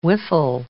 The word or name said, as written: Wiffle